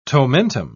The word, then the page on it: tomentum: British English